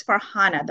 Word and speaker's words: Farhana: My name is Farhana, the way you spell it, F-A-R-H-A-N-A, very simple, right? But it was Farina.